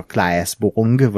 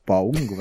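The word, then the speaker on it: Claes Bang: és lehet, hogy rosszul mondom a nevét, de az a Klaes Bong, vagy Beon, Paung, vagy Igen, pont ilyesmi. így ejtik így.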